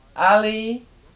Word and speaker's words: Ali: T-E-N, ten. Nying, N-Y-I-N-G. Kali, K-A-L-I. Dang, D-A-N-G. This is the Kali dissolving now. Ali, the Ali has long A, and the A in Kali was also long A. Ali, Padma, P-A-D-M-A, the alley and the lotus, Da, D-A, D-A-N, Dan, Nam, N-A-M, N-G-A, Nga, Ma, M-A, Chir, C-H-I-R, Tim, T-I-M, B-J, B-I-J-A-Y, Gyur, G-Y-U-R.